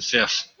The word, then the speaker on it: Fiffe: Capra by Michael, I'm going to pronounce it Fiff, F-I-F-F-E.